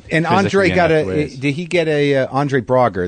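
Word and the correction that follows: Braugher: is that how you pronounce it? – Brauer.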